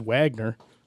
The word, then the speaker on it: Wagner: And this Wagner group, that from when he pronounced it, the dude that runs this group says Wagner. So I'm like, okay, it's Wagner.